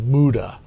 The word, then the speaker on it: muda: It was called MUDA. Tell us am I pronouncing it correctly? – You are.